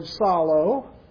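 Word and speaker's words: Psalo (ψάλλω): And it says in the word for singing there, by the way, in verse 19, is the Greek word psalo. Psalo is the way you would pronounce it in Greek.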